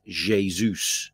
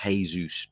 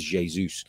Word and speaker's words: Jesus: Somebody rightly points out in the chat that it's pronounced Jesus, not Jesus, because it's Portuguese.